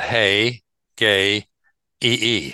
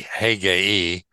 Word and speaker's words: Hegai: I looked it up and they pronounced it Hey Gay E, Hegai, the king's eunuch.